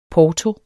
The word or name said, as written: porto